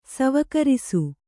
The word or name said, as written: ಸವಕರಿಸು